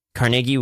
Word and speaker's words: Carnegie: They were just like, Rockefeller is vertical consolidation, and Carnegie was horizontal consolidation. – See, that's how I know you're a Pittsburgh native, is because you pronounced it correctly Carnegie? – As Carnegie, and not Carnegie.